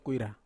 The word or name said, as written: kuira